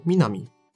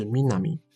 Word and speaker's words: 美波: 美波 Minami，本名没有公开。而他的团队 logo 上头写着 Team 三七三，三七三的日文读音与他的艺名读音相同，都是 Minami，但这似乎也没有办法推敲出他取这个名字的原因哦，非常的神秘。